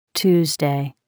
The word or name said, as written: Tuesday